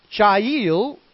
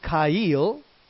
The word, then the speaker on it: chayil: It's the word chayil, and sometimes people pronounce it kail with a ka sound instead of the cha sound. Kail or chayil.